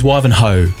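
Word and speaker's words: Wivenhoe: But you still pronounce the H in Wivenho. – The way, just off the top of my head, the pronunciation is Wivenho.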